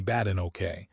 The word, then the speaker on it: Badenoch: When opposition leader Kemi Badenoch, pronounced Kemi Badenoke, called her agenda spiteful class warfare, Phillipson took it as a compliment.